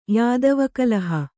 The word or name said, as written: ಯಾದವೀಕಲಹ